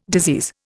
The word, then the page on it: disease: Indian